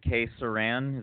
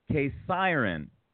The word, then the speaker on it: K-Syran: And check out the website, which is, and I hope I'm pronounced, is it K-Syran? Is that right? – Yes, I mean, in English, it's a K-Syran, but it doesn't matter. Like a siren, you know? – K-Syran.